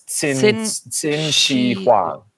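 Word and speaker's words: Qin Shi Huang: The Q is like a TS, so it's Xin Shi Huang.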